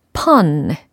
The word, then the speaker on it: pun: fun, pun. 발음이 분명 다릅니다. 그쵸? 그래서 F 사운드와 P 사운드를 또 비교를 하는 시간입니다.